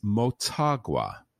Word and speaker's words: Motagua: That's Motagua, or as Griffin pronounces it, go Sounders.